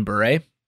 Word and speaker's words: Bure: You mean Bure? – Oh, is that how you say it? It's, yeah, Bure? – It's Bure.